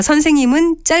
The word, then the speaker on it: 선생님: 그러나 선생님은 짧게 발음하는 것이 맞고 어른은 긴 어로 발음하지만 의와는 다른 발음이라는 것입니다.